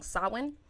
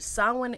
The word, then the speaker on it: Samhain: So the Celtics actually have a holiday called, I'm going to pronounce it wrong, Samhain. Mm-hmm. And Samhain is their holiday, which we kind of know of as Halloween. – Or Samhain. – It's pronounced like S-A-W.